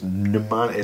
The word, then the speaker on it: mnemonic: Yeah, and then M-O-N-I-E. See, so it's M-N-E. Yeah. Like mnemonics. It's, you don't pronounce the M, the first M. You do pronounce it with an N. So it's like, it's spelled with an M. It's spelled with an M-N, but you say N. So it's mnemonic, like Captain Nemo.